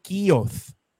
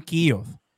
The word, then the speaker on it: Keogh: se llama un plan KIOS. Se escribe K-E-O-G-H. Plan KIOS.